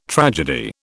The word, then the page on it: tragedy: British English